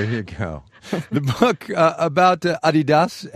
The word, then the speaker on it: Adidas: You've been saying Adidas, as in Adidasler, Adidas. Over here we say Adidas. Which one's right? – Oh, definitely the European pronunciation, and it's really strange. I really can't explain why you pronounce it differently in the US.